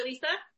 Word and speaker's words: Liisa: I'm the other Lisa, and it's not a typo. First name is spelled L-I-I-S-A, so that's how you'll, it's two I's here.